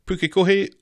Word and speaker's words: Pukekohe: He really enjoyed our latest episode, and he says that my pronunciation of Pukekohe was spot on too.